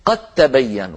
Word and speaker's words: قد تبين: العرب تقول قد وتظهر الدال وتقلقلها، لكن لو جاء بعدها تاء يقولون قد تبين، قد تبين، ولا ينطقون الدال ولا يقلقلونها، بل يجعلونها تاء ويدغمونها في التاء التي بعدها فيقولون قد تبين.